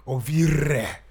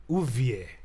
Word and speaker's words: Ovie: Ovire. How do you pronounce it? – Ovie. – Why do people say Ovi then? – Because it's O-V-I-E. – Ovie. – It's not Ovie.